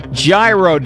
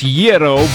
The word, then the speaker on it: gyro: I know it's pronounced Yero, but Yero. I can't even say it right anymore. It's a gyro. This is America. We don't pronounce stuff right.